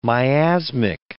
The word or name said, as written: miasmic